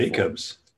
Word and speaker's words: Jackobs: Jackobs. – Jackobs. – This with an extra K. Special K.